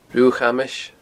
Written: Rubha a' Chamais

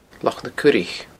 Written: Loch na Curaich

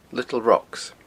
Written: Little Rocks

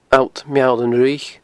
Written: alltmeallanfhraoich

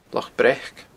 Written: Loch Breac

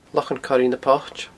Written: Lochan Coire na Poite

